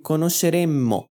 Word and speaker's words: conosceremmo: Note que eu pronuncio conosceremmo com dois M's.